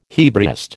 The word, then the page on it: Hebraist: American English